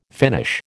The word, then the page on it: Finnish: American English